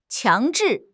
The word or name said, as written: 强制